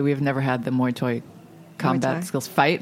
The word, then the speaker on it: Muay Thai: Very close to Mai Thai.